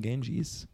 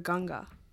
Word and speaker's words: Ganga: I like the name Ganges. – It's actually the Ganga. – Oh, I'm pronouncing it wrong? – Well, that's the westernized way to say it. Hmm.